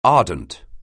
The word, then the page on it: ardent: anglais